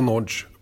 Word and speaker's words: Nagy: Idag är det Dennis Nodge med stor respekt för uttalet här, men Nagy brukar vara Nodge om man, är, mm. om man har ungersk börd.